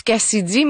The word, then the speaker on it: KCIDY: parlons maintenant de l'artiste Cassidy, qui se prononce Cassidy, mais ça s'écrit K-C-I-D-Y.